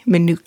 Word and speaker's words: Minooka: Nick, you were born in the little town of Minooka, Illinois. Did I pronounce that correctly? Minooka. – That's right, yes. – And Minooka.